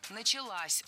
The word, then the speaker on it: началась: Опять же, возвратная часть «с» притягивает ударение к себе. Началась. Ударяем последний слог.